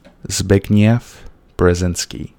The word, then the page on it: Brzezinski: American English